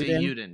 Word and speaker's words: Eiyuden: It's Eiyuden. Yeah, there's no L.